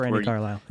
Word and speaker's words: Brandi Carlile: the Brandi Carlile, mm-hmm. is that how you say her name? – By the way, I forgive you. – Okay, yeah, by the way, I forgive you post. – Brandi Carlile, yeah.